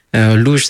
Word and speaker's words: Luz: Et pour répondre déjà à des questions qu'on m'a posées auparavant, Louge, ça s'écrit L-U-Z. Alors pourquoi pas le Z dans l'Usagora? Simplement parce que visuellement, ça faisait une consonance très hispanique, d'origine d'Espagne, quoi.